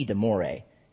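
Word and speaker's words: de Morès: He was ranching, and he received a letter from a wealthy landowner, neighbor that lived close to him, the Marquis de Moray. Am I pronouncing that right? De Moray? Ah, thank you.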